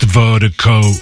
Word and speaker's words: Virdiko: And Vertigo is spelled V-I-R-D-I-K-O. That's V-I-R-D-I-K-O, pronounced Vertigo.